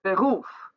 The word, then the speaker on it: Beruf: Beruf, Gespräch both have two syllables, and the last syllable is accentuated.